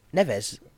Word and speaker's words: Neves: Neves.